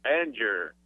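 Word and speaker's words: Angier: How do you pronounce, is that Anger? – Anger.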